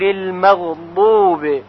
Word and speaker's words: المغضوب: غیر المغضوب، پس اگر بگوییم غیر المغضوب نماز باطل هست.